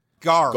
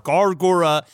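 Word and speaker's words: Gawr Gura: And Gargura, how do I say that? Gaur? – Gar. – Gar. – Like, Gur. – Gar. – Gar. – Gargura. – Not and Gwar.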